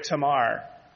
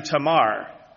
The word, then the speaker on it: Tamar: So, just for some context, today's passage is from the 38th chapter of Genesis, which introduces us to Tamar. Now, in the South, I always called her Tamar. But in seminary, I learned that I was always putting the emphasis on the wrong syllable.